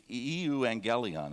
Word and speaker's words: euangelion: The good news, the gospel itself, as you look at verse 1 of Mark's gospel, is in the Greek, it's euangelion. That's how you'd pronounce that word.